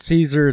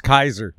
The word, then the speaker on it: Caesar: It's not Caesar, it's Kaiser.